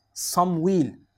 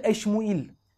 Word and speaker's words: Samuil: Samuil. Ya da başka bir okunuşta Eşmuil. Yani Samuil diye de okunuyor. Eşmuil diye de okunuyor.